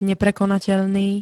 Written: neprekonateľný

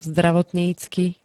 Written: zdravotnícky

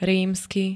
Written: rímsky

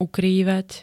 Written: ukrývať

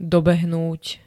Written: dobehnúť

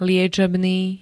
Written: liečebný

